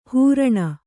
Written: ಹರಿಣ

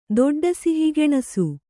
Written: ದೊಡ್ಡಸಿಹಿಗೆಣಸು

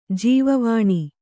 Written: ಜೀವವಾಣಿ

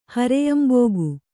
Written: ಹರೆಯಂಬೋಗು

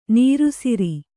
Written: ನೀರೆಸರು

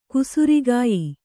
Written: ಕುಸುರಿಗಾಯಿ

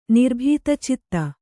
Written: ನಿರ್ಭೀತಚಿತ್ತ

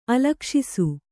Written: ಅಲಕ್ಷಿಸು

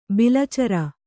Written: ಬಾಲಚಾರ

